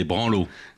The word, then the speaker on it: branlos: Non, on dit des branlos. Y a pas le, on prononce pas le S.